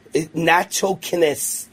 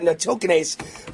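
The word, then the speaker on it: nattokinase: And he stayed healthy by taking, he sent this to me yesterday, natokinase.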